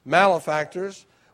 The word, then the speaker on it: malefactors: Verse 39, and one of the malefactors, by the way, that's the pronunciation, malefactors, which were hanged, railed on him, saying, if thou be Christ, save thyself and us.